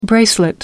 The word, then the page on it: bracelet: American English